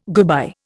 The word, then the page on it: goodbye: Indian